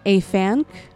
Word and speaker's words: Afanc: I'm going to probably say this wrong, but Afanc, A-F-A-N-C, like a fan with a C at the end.